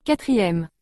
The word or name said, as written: 4e